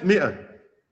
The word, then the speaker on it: مئة: الزيادة، الزيادة زي ألف مئة، مئة، إحنا بننطق الألف، ما بننطقوش.